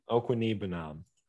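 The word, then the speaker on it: Okwuegbunam: Aaron Rodgers, Jonathan Taylor, Aaron Jones, Mike Evans, Allen Robinson, Albert – how do you say his last name? – Okwunibanab.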